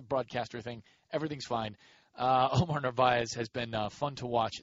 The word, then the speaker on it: Narváez: You could have a kind of a budding guy in Omar Narváez, whose name, by the way, is pronounced differently by everybody who gets to pronounce it. Some people put the R after the V where it actually is, kind of like a Brett Favre situation.